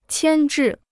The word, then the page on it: 牵制: qiān zhì